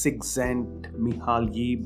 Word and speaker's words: Csikszentmihalyi: So if you ever try to search that on Google, you'll never find it because it's spelled as Mihaly, M-I-H-A-L-Y. His last name is C-S-I-K-S-Z-E-N-T-M-I-H-A-L-Y-I. So as it's spelled, it's Csikszentmihalyi, but it's pronounced as Csikszentmihalyi. So his name is Mihaly Csikszentmihalyi.